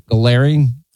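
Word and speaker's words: Gallrein: How do you say his name? It's Ed Gallarine.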